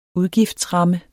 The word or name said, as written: udgiftsramme